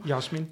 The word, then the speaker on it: Jasmin: Eh, Jasmin, zeg ik het goed? Welkom. – Jasmin. – Oh, Jasmin, oké. Welkom. Stel jezelf even voor als je wil. Nou, mijn naam is eh, Jasmin Pekaric en ik ben onderwijsmanager aan de Hogeschool Leiden Fysiotherapie.